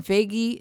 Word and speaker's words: Feige: When Feige, is that how you pronounce his name? Mm-hmm.